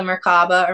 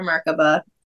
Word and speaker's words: Merkaba: But so the Merkaba or Merkaba, pronounce either or, it means chariot in Hebrew, and it is two decahedrons, that's a mouthful for me, stacked together.